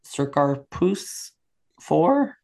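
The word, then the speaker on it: Circarpus IV: He is heading to a planet, which I believed is pronounced Circarpus 4, but which I'm going to pronounce Circus Puss 4.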